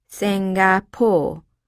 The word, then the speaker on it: Singapore: She pronounced it Singapore, with the accent on the last syllable.